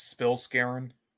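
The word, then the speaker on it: Svenskeren: Also, you mispronounce his name. It's Spill Skaren.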